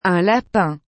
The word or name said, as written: un lapin